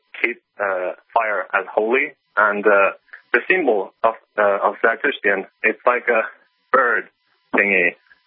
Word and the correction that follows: Zoroastrians: Sure, you're talking about the Zoroastrians? – I have no idea how it's pronounced.